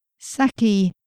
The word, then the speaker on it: Saci: Authors Kate Pullinger, Saki, that's spelled S-A-C-I, Lloyd, and Anna Anthropy discuss digital writing and representing the digital experience for diverse fiction readers.